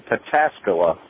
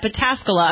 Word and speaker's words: Pataskala: How do you say it? – Well, Sexy Crabtree proudly resides in Pataskala, Ohio, which is near Columbus. – I'm sorry for mispronouncing that, Pataskala.